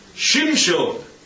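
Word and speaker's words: Shimshon (שמשון): Because do you know how they pronounce his name in Hebrew? I don't suppose you do. It's Shimshon. Shimshon. Not Samson.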